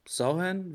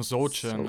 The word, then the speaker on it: Sochan: So, äh, Sauhan wird ausgesprochen, ne? Der mit dem bunten Haaren. Ich sage immer Sochan, so aber ja.